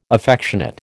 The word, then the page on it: affectionate: American English